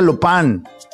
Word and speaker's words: Lupin: Se llama, eh, se escribe Lupin, pero se pronuncia Lupin.